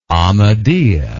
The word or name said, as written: Ahmadiya